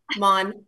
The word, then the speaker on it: Maughan: It's Maughan, really close.